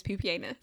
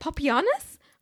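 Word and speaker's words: Pupienus: And I think the actual pronunciation, I believe the way you pronounce his name is Pupianus. – Pupianus. – It must be. Is it Papianus? Papianus? It's Pupianus.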